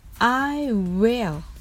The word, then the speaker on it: will: I will つまり日本語のうの音もるの音も実際には発音をしません。流れとしては W の口の形次に「い」はきちんと発音しますそして舌先で上顎の前歯の付け根をなぞる